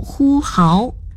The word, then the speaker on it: 呼号: 是奔走呼号，号第二声。 – 为什么？ – 奔走就是奔跑，呼号是喊叫，号码的号读成第二声号，意思是拖长声音大声叫唤。